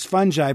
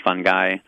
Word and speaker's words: fungi: By the way, someone wonders, Diane wonders, why is fungi pronounced the way it is when fungus is pronounced with a hard G? – No.